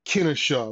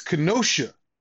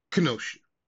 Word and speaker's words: Kenosha: It's not Kennesaw, I believe I was pronouncing Kennesaw, Wisconsin. It's Kenosha, Wisconsin. So, Kenosha.